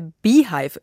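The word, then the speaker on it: Beyhive: Wissen Sie, was der Beehive ist? Also mit Y in der Mitte. Das ist die Fan-Community von Beyoncé, spricht sich wie das englische Wort für Bienenstock.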